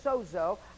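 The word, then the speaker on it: sozo (σώζω): It's actually looks like sozoe when you look it out. You know, it's S-O-D-E-Z-O-E. That's how it is. What's that called in the parentheses? The pronunciation. But the spelling is S-O-Z-O. So it just sounds easy to say sozo.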